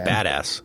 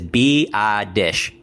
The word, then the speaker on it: Biadasz: No, I think it's it's Biadasz. I mean, I just, but, hey, one of my favorite things, seriously, out of in Dane's Beast is the pronunciations. He has them for almost everybody. And yeah, he has it as B-I-Dish. B-I-Dish.